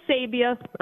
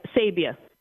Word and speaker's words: Sabia: Okay, Sabia. I'm very proud of him. – Spell it. – And I'm also Sabia. Sabia. – Spell, please. – Oh, S-A-B-I-A.